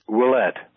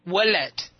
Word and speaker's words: Ouellette: could you pronounce his last name, please? – Ouellette, French, Canadian. – Ouellette. – Okay. Yes.